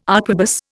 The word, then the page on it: arquebus: Indian